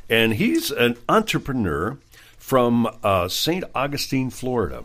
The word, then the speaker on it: St. Augustine: St. Augustine.